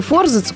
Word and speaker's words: форзац: В немецком языке в слове форзац ударение падает также на первый слог.